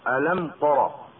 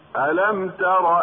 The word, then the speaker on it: ألم تر: فمثلا ألم ترى، التاء مستفلة وهي مجاورة للراء المفتوحة المفخمة، فلو طغت الراء المفتوحة على التاء لجعلت التاء طاء، كما نسمع خطأ من يقول ألم ترى، وكما نسمع خطأ من يفخم التاء لطغيان تفخيم الراء المجاورة لها، هكذا من نحو ولا أكفر. والنطق الصحيح هكذا: ألم تر أن الله يعلم ما في السماوات وما في الأرض.